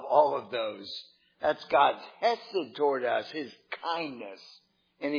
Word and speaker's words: chesed: And that's our favorite word, chesed. If someone asks how you spell that, you could spell it like this. C-H-E-S-E-D. It's kind of a hard sound at the beginning. Chesed.